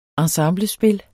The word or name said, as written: ensemblespil